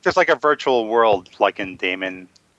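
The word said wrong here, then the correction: Daemon: Demon. It's called Demon. Yeah.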